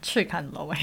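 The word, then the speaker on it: Chikanlo (赤崁樓): First thing first, I was surprised about your pronunciation of Chikanlo. It's really, really accurate.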